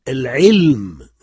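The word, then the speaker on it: al-ilm (العِلْم): Et le deuxième, al-ilm, la science.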